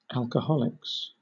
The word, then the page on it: alcoholics: British English